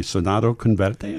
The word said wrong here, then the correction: Soñando con verte: is that right? – Soñando Converte.